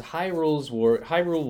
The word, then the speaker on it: Hyrule: This is Hyrule Warriors.